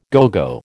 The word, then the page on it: à gogo: American English